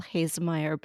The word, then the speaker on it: Haesemeyer: I'm very excited to have Paul Haesemeyer back with us today.